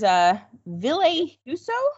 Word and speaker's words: Ville Husso: And then there's Ville Husso.